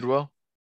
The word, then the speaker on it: Tidwell: We got Rod Tadwell. Is that how you pronounce it? – Tidwell.